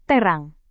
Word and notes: terang: indonesian

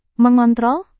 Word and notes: mengontrol: Indonesian

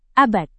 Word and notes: abad: indonesian

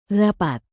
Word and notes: rapat: indonesian

